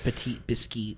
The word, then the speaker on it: Petit Biscuit: It's spelled biscuit, but it's pronounced Petit Biscuit.